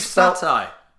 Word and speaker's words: satay: Beef satay. – It's spelled S-A-T-A-Y. – Satay. – If there was no A beef at the beginning, satay. It would be beef stay.